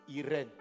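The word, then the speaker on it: Irène (εἰρήνη): Il y a un autre mot pour dire shalom en grec, c'est le mot Irène. Irène. Comme les gens qui s'appellent Irène. Qui s'appelle Irène Voilà, mais bon, ça ne s'écrit pas comme nous, Irène. ça s'écrit E-I-R-E-I-N-E.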